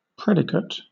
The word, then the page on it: predicate: British English